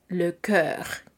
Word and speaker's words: cœur: este se escribe C-O-E engarzada, la O y la E, y posteriormente U-R, le cœur, que es el corazón.